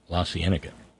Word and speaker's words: La Cienega: Okay, La Cienega. Thank you, Fabio. La Cienega. Thank you. That's how Tom Waits pronounces it.